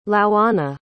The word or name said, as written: louanna